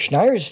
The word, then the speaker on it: Schneier: So when I first read Gruber's little article where he mentioned Schneier's article, and I'm pronouncing that right too, right? – You are correct.